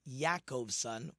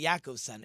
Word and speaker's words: Yaakovson: Like when I was in, my great aunt told me in Europe, Jacobson is really supposed to be pronounced Yaakovson with a yud, like Yaakov. It's really Yaakovson.